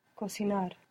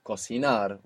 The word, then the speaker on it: cocinar: cocinar. Cocinar with the accent on the end. Cocinar.